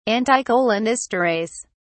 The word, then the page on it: anticholinesterase: British English